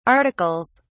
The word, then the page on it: articles: British English